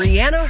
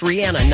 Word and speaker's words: Rihanna: And a few years ago, Ellen had Rihanna on her show, and Rihanna herself said, yeah, her name is pronounced Rihanna, not Rihanna.